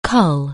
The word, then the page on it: cull: anglais